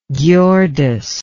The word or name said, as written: Ghiordes